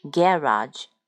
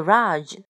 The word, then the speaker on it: garage: 今天呢，我们说一个单词叫 garage，garage，它有两个读音。美语的话，一个是读最后的那个辅音是 z，一个是 z，garage。我还是比较喜欢念 garage。然后呢，它的英式的发音呢是 garage，或者是 garage，或者是 garage，garage。那么呢，我们来看一下，还是记住美语的发音，garage，garage。那我们看看这个 garage 有两个意思，一个是停车房、车库的意思，比如说，嗯，内设车库的房子，内设车库的房子，那强调的是房子。